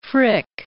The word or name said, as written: Frick